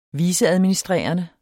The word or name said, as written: viceadministrerende